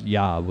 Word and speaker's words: Yob: So my last name is Yob, which is spelled Y-O-B.